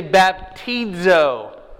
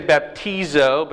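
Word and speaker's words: baptizo (βαπτίζω): So say baptizo, baptizo, right? Not real hard at all. We could say baptizo, but we kind of missed the pronunciation in Greek. Baptizo, so I translate that letter, transliterate that letter with a D and a Z.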